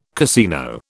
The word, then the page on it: casino: British English